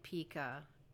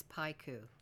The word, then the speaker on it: pica: We've been pronouncing it pica, but it's paiku.